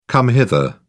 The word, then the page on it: come hither: British English